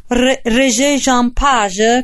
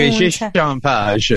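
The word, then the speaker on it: Regé-Jean Page: Lui è Regé Jean Page, così si pronuncia. – Regé Jean Page, esattamente.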